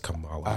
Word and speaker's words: Kamala: Kamala, or however the fuck you pronounce that. – I think that. – It's Kamala. – I heard it's like Pamela with a K.